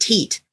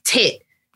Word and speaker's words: teat: The word T-E-A-T that people used to refer to. – Yeah. – No. – It's not supposed to be said teat. – Oh. – That's really modern. It's tit. – Oh. – T-E-A-T is pronounced tit.